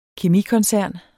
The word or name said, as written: kemikoncern